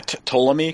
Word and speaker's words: Ptolemy: They're obviously talking about the reign of the new pharaoh, which is how do you pronounce that? Ptolemy? It starts with a P, but I don't think there's a... – Yeah, Ptolemy, because it's Ptolemaic. – Ptolemy, yeah. – Yeah, I think the P's silent.